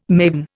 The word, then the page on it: maven: Indian